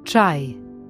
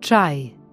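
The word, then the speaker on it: čaj: Der Buchstabe C-Apostroph wird wie das deutsche Tsch in Tschüss ausgesprochen. Ein typisches Beispiel ist Chai, was Tee bedeutet. Also Chai. – Tee. – Chai.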